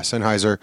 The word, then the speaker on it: Sennheiser: But I do now I am now the proud owner of a couple of Sennheiser. Is that how you say it? Sennheiser. Is that it? Show me the page. Sennheiser. Yeah, Sennheiser.